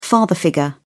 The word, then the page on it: father-figure: British English